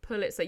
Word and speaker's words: Pulitzer: How do you say it? Pulitzer. We're not winning one of them. – Pulitzer. Yeah, yeah.